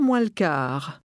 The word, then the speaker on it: moins le quart: À 8:00 moins le quart. Quart. Le quart. Moins. Moins le quart. Moins le quart. À 8:00 moins le quart. – Notice how the words run together.